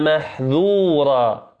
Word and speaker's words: محذورا: ولذلك قال وخلص انفتاح محذورا محذورا بالذات محذور، إن عذاب ربك كان محذورا بالذال.